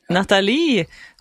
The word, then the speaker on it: Natalie: Ja, Natalie, som han altså da sier på fransk, Natalie, og 30 år, og kom inn I livet hans, og nå beskrevet som den som følger han nærmest.